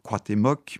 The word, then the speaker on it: Cuauhtémoc: Gatimozin, auquel nous avons rendu son nom aztèque de Cuauhtémoc, excusez-moi, je prononce très mal l'aztèque. – Oui, vous prononcez très bien l'aztèque, je trouve.